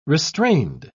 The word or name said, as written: restrained